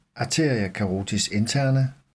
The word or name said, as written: a. carotis interna